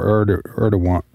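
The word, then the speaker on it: Erdoğan: President Trump said the only reason he was going, basically was for Erdogan.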